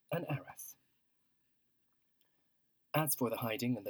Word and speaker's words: Arras: And just so you know, the town of Arras, A-R-R-A-S, pronounced in English Arras,